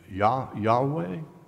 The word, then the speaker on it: Yahweh: And how do you pronounce that? The best guess is Yahweh, something like that.